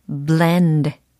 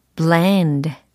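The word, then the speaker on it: bland: blend, blend.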